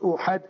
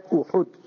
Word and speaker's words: Uhud: Third year of the Hijrah, 1 year later, the month of Shawwal, Sahaba Mustafa sallallahu alaihi wasallam, Sahaba come out to Uhud, not Uhad, Uhud. Learn the proper pronunciation.